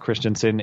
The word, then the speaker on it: Christiansen: Uh, if you're if you're looking to find me, the name is Matt Christiansen. It's Christian like the religion, S E N.